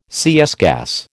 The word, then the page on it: CS gas: American English